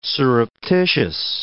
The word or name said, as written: surreptitious